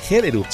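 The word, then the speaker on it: Gjellerup: Gellerup será Premio Nobel de Literatura en el año 1917. – A lo mejor esa J suena como I.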